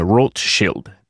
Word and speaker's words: Rothschild: The reference to Mr. Rothschild, or I prefer to use the pronunciation Rothschild, the German, because it actually gets to the origin.